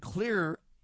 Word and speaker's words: Xlear: And Clear is spelled like X-Lear. X-L-E-A-R. X-Lear or Clear. C-L-E or X-L-E-A-R.